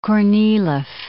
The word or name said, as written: Kornilov